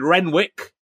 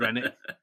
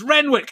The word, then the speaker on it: Renwick: And I love the fact that he corrected you, because I would have called him David Rennick.